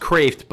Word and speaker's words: Kreeft: So it's pronounced Kraft, but it's spelled K-R-E-E-F-T.